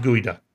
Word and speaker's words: geoduck: Okay, gooey that's duck. geoduck. No, it's it's pronounced Guida.